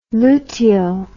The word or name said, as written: luteo